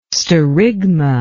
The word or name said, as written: sterigma